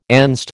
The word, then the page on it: Enzed: American English